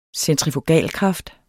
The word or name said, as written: centrifugalkraften